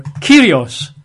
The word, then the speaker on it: kurios: They use the Greek word kurios, K-U-R-I-O-S, or pronounced kurios, I believe.